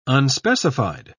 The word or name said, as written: unspecified